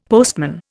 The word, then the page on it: postman: Indian